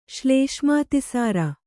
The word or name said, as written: ಶ್ಲೇಷ್ಮಾತಿಸಾರ